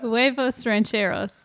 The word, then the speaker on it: Huevos Rancheros: I just had a Havros Ranchos. Did I say that right? – Huevos Rancheros.